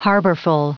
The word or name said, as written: harborful